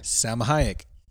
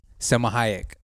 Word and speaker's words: Salma Hayek: Okay. Selma Hayek? – No, that's kayak. – So you tell me what it is. – I just did. – What? – Salma Hayek. – Salma Hayek. – There you go. – That's the real way to say it.